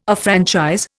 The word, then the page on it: affranchise: Indian